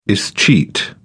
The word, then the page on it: escheat: British English